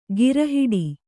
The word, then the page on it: ಗಿರಹಿಡಿ: gira hiḍi